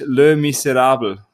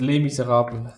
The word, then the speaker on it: Les Misérables: Aber der Originaltitel ist Le Miserable. – Klingt das ah. gut? Ja. – Habe ich es richtig ausgesprochen? – Das heißt Les Miserables.